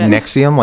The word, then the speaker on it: NXIVM: Nexium, like the drug? – Oh, yes. – Okay, tell me. – But it's spelled N... How does it spell? N-X-I-V-M.